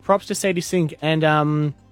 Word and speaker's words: Sadie Sink: And Sadie Sink, is that how you say her name? – Yeah.